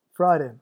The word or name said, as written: friday